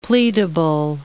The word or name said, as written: pleadable